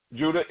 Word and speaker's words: Judah: Judah is It's pronounced Judah.